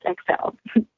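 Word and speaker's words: Xelle: So that's X-E-L-L-E pronounced XL.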